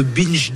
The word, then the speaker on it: binge: binge drinking. C'est bien comme ça. – Binge. – Ah ben c'est parfaitement prononcé.